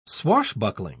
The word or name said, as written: swashbuckling